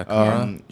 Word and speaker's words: Kara: Am I saying that right? I think it's Kara. – Yeah, Kara.